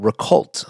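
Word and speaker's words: Récolte: He currently has a very fun project called Recolt. I hope I'm pronouncing that correctly. It is spelled R-E-C-O-L-T-E, and it is a dessert bar on the Upper West Side of New York City.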